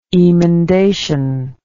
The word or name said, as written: emendatory